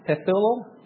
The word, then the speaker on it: Pethuel: His father was Pethuel. That's how you pronounce it.